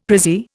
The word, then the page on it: prezzie: Indian